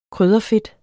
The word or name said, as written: krydderfedt